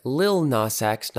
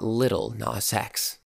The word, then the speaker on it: Lil Nas X: It's Adriana Lima, not Ariana Lima, and Lil Nas X, not Little Nas X.